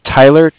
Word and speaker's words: Tyler: My name is Tyler, T-Y-L-E-R, and I'm calling with Cardiff. It's spelled C-A-R-D-I-F-F, as in Frank, Frank.